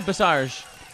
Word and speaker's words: Bassage: Now, I can't pronounce your last name, so I'm not even going to butcher it. Is it Bassage? – Very good, Bassage. – Is that what is that again now? – It's French. – French. – It's Cajun.